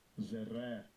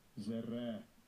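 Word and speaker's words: Zerah (זרע): Zerah. – Zerah.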